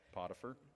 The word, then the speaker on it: Potiphar: Potiphar, is that pronounced? – Correct. Potiphar.